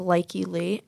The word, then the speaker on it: Lykke Li: It was like the Magician remix by, I want to say it's pronounced Likely Lee. L-Y-K-K-E-L-I, Lee.